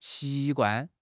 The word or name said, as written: xi2 guan4